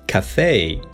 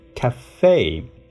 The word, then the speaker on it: café: 成 cafe，生活中经常看到它。